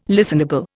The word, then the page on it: listenable: Indian